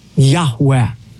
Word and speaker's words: Yahweh: In Hebrew, it would be composed of the four Hebrew letters, Yud Hey, Vav Hey, which most Semitic scholars believe is pronounced a breathy Yahweh.